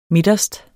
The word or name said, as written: midterst